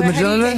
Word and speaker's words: Mjolnir: Jolnir? – It's spelled M-J-O-L-N-I-R. – Mjolnir. – He's the geek that'll know how to pronounce that. – Mjolnir? – Is it Mjolnir? – Here we go.